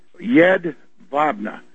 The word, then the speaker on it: Jedwabne: He wrote before about some kind of Polish participation in mass executions at Jedwabne in northeast Poland. And it's Y-E-D-V-A-B-N-E. That's how it's pronounced in German.